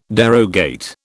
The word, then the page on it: derogate: British English